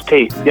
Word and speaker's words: Taitt: Yes, it's Taitt.